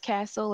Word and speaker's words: Castle: Um, uh, you can find me on Psychology Today, Konstance Castle, last name spelled C A S T L E, first name spelled with a K.